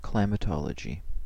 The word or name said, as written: climatology